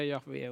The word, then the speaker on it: Jahve: J-H-V-H. Vi vet inte exakt hur det ska uttalas, men vi brukar säga Jahve ungefär.